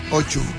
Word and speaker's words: O2: El histórico concierto homenaje a Ahmet Ed Ertegun en el O2 Arena de Londres. – O2. – O2. – O2. – ¿Pero no se dice Chu en inglés? – Sí, Ochu. O2.